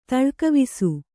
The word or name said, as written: ತಳ್ಕವಿಸು